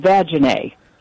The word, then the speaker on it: Vagina: And she said, and it was spelled vagina. And the student says, it's pronounced vaginae.